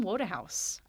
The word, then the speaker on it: Wodehouse: So uh, Mr. Wodehouse's name is spelled W O D E H O U S E. – Um, and I was consistently pronouncing it Wodehouse mm-hmm. until I was corrected. Mm-hmm. And Woodhouse just sounds too plebeian.